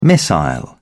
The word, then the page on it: missile: British English